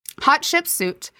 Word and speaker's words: Hatshepsut: Hatshepsut, and my Egyptologist told us to say it like hot chicken soup.